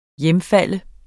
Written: hjemfalde

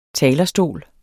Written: TALERSTOLE

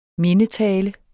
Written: mindetale

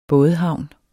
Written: bådehavn

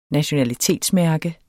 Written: nationalitetsmærke

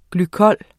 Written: glykol

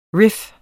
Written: riff

